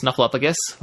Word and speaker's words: Snuffleupagus: Uh, Big Bird and Patty have snuffleupagus, and that is how you pronounce that, everyone, if you're wondering.